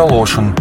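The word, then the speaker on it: Larsson: есть э, исполнительница Зара Ларсен, но на родине в э, Швеции она Зара Лошин.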